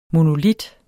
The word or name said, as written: monolit